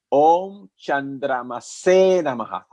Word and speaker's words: Om Chandramase Namaha: Om Chandramase Namaha, con acento en la última E. Y se canta así. Om Chandramase Namaha.